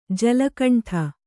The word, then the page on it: ಜಲಕಂಠ: jala kaṇṭha